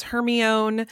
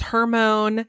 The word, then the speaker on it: Hermione: Like, some people told us it was Hermione. Some people told us it was Hermone. – Hermone. – Yeah. It honestly wasn't until, I don't think it was until the movies came out that I learned the right way to say her name.